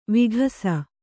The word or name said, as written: ವಿಕಾಸ